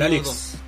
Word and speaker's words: Alyx: Hades, Doom Eternal, Street of Rage 4, Half-Life Alyx, ah, o Alex, no sé cómo se pronuncia. – El Alex, el Alex. Esta para mí está difícil.